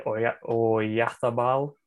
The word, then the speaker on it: Oyarzabal: It all came down to a penalty, which was tucked away by Oyarzabal. I hope I said that right, my Spanish-speaking friend. – Yeah, close enough.